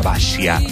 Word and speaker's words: Basia: Se pronuncia en realidad Basia, su nombre. Es polaca. Y este 30 de septiembre está celebrando 69 años. Se escribe Basia con S y se pronuncia Basia. Yo le digo Basia al castellano, pero es Basia.